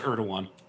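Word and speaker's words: Erdoğan: Is it Erdogan? – It is pronounced Erdogan.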